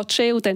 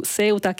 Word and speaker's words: Ceuta: Adesso ho deciso di utilizzare la versione italiana della pronuncia, quindi dirò Ceuta e non più Ceuta, che è la versione spagnola, ma appunto noi diciamo Londra e non London, e quindi Ceuta.